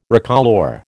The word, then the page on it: recolour: American English